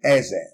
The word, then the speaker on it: Eze: An example is Eze, the word in Eze, my mm-hmm. name, E Z E. So that is pronounced in Igbo, E.